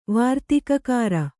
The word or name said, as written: ವಾರ್ತಿಕಕಾರ